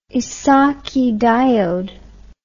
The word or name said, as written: Esaki diode